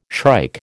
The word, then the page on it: shrike: American English